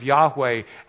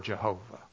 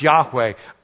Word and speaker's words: Yahweh: There is no Hebrew word Jehovah. It is probably, we're not even sure, pronounced Yahweh. I am.